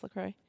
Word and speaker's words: LaCroix: No, that's how you say it. It's just LaCroix. Because LaCroix, Wisconsin is a real place.